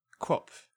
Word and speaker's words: QWOP: So, I would have to say that my first official entry into my most unique games would be Quap. Have you ever played this one? It's like a browser game, actually, or like a flash game. It's spelled Q-W-O-P. – Quop.